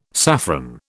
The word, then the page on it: saffron: British English